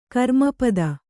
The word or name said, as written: ಕರ್ಮಪದ